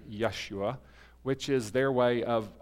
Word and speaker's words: Yeshua: And actually, the Jewish or the Hebrew pronunciation would actually be Yeshua.